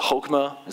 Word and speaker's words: chokmah: It's a chokmah, is how you say it.